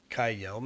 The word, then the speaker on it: Kjell: Now, this is when Nico came to Kaldheim, this is where he met K-J-E-L-L. No card, nothing. This is the only story he pops up. – Kyle, maybe? Because if it's a J, it's a soft J in Norse, in Nordic.